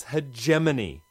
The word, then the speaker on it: hegemony: Hegemony? – Oh, jeez. No wonder it's frayed around the edges. Words are not meant to stand up to their secondary pronunciations. It's hegemony. Hegemony.